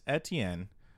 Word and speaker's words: Etienne: And that is Travis Etienne will finish as a top 5 fantasy back.